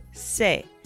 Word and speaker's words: sé: And it really doesn't change the pronunciation in this course.